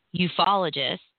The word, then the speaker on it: ufologists: Documents reported to be from this group have surfaced, mostly as discoveries of ufologists. What, did I say that right? Did I do it right? – Yeah. – Yes.